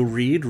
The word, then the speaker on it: Reid: You can find me on Twitter at Joe Reed. Reed is spelled R-E-I-D.